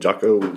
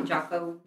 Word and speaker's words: Jocko: And uh, so I, I think it was Jocko Wilkes. Isn't that how you say his name? Jocko. – Jocko. – Yeah, Jocko.